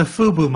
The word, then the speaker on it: FUBU: Wait, how do you say it again? FUBU I know it's a new one. – Fu- Fubu.